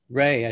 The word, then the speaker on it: Rhea: Do you pronounce her first name as Ray? Ray Seahorn? Rhea Seahorn? – Ray, I think. – Ray would be how we would say it in Ireland. – Yeah. – R-E-H-E-A would be Ray.